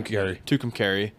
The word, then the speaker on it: Tucumcari: Tucumcari. – Tucumcari.